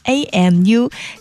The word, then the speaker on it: AMU: 这两个好朋友在 2016 年的时候组建了这样一支乐队组合吧， AMU，藏语音译过来发音是阿木，少年的意思。